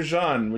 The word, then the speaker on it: Jeanne: So, as usual, something super important dropped right after we recorded, but they revealed the suit for Kamen Rider Jean, which is Sakura's rider form. – And is that how you pronounce it? – Yeah, it's like Jeanne d'Arc.